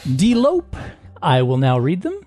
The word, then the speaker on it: delope: Delope. Delope. That's spelled D-E-L-O-P-E.